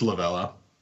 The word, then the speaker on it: Lovullo: I'll start this week with my Madden moment of the week was Torey Lovullo. I said that wrong, didn't I? Is it Lovello? – It's Lovello.